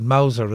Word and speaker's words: Mauser: Is it a Mauser the German army had? Is that how you pronounce it? Mauser, is it? – Yeah.